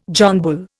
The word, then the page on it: John Bull: Indian